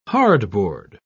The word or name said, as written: hardboard